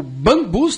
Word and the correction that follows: Bambos: tá certo, Alexandre, a pronúncia? – Cara, ele falou lá na parada, o nome dele é Bambos. – Bambos. – Eu queria... Bizarro, ninguém nunca ia adivinhar isso.